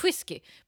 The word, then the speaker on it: whiskey: This is also the kind of beverage that begs to have the H in whiskey pronounced. Whiskey.